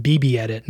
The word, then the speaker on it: BBEdit: Oh, that's called, it's not, it's pronounced BBEdit, not bug.